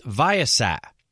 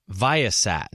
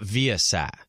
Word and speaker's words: Viasat: I've always known Viasat to be pronounced Viasat. Okay, well, there you go. I didn't know that. Viasat, not Viasat.